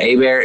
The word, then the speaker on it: Hébert: Hébert is spelled like Hebert, not Herb, not confusing at all, right? – And my last name is also very weird.